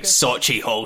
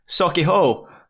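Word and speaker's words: Sauchiehall: Sochi Hall Street. Jeez, oh, that is not how you say that. – Go on, how do you say it? – Sochi Hall.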